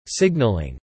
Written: signalling

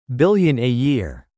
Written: billion a year

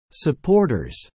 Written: supporters

